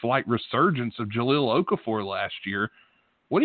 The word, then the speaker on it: Jahlil Okafor: Yeah, I think you said it right. It's going to be Jahlil Okafor,